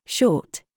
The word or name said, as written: TIGHT